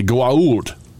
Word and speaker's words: Goa'uld: I will spell it for you. It is G-O-A apostrophe A-L-D. – Yes, the Goa'uld. That is what the word is.